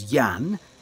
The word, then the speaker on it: Jan: Jan Kovalik. – Can you spell that, please? – Yes. My first name is Jan. That's J-A-N.